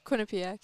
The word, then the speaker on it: Quinnipiac: Does uh Quinna Quinna Quinnipiac. Am I saying that right? – Quinnipiac, yeah.